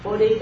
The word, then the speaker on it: Bodhi: Bodhi đó,